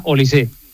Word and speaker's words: Olise: Lleva acento, pero se pronuncia Olise.